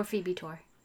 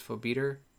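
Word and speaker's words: Phobetor: I think the most common pronunciation or the most correct is Phobitor. But that's not how I pronounced it in this episode.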